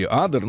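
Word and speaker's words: Ader: Французский инженер Климан Адер, или Адер, ну, у французов, как, как правило, ударение на последний слог, э, на своем аппарате с паровым двигателем, двумя винтами и крыльями, которые напоминали птичью или, ага.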